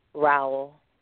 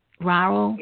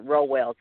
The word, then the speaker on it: Rowell: Raul. Raul. Yes. yes. – For some some reason, my mouth just don't. – and some people um pronounce it Rowell too.